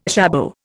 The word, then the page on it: jabot: Indian